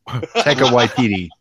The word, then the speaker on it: Taika Waititi: It's Waikiki. Okay. However you say his name. Taika Waititi. – You nailed it.